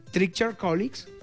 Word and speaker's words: Treacher-Collins: ¿Cómo se pronuncia? Qué mal inglés. ¿Treacher-Collins? – Collins. – Sí, bueno, estuvo bien, qué buen inglés.